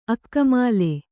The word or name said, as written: ಅಕ್ಕಮಾಲೆ